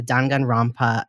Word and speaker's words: Danganronpa: You brought to us the Danganronpa. Am I saying that right? – Yes.